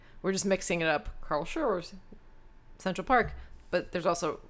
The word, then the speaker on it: Schurz: We went to Carl Schurz. It's hard to pronounce that. – We lived on Carl Schurz Park for like 6 years. – Yeah, I know. – Schurz.